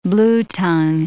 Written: bluetongue

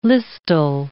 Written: listel

